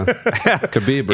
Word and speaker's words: Khabib: McGregor hasn't fought in MMA since losing to Khabib Nurmagomedov. Did I say that right? – Close. – Yeah. Khabib.